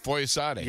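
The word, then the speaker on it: Foyesade: Was that how you say it? Foyisade. – Yeah.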